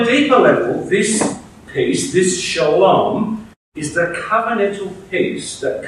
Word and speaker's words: shalom: At one level, "peace be with you," or what would be pronounced as "shalom," is the standard everyday greeting for Jewish people.